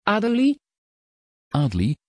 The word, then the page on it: adelie: Dutch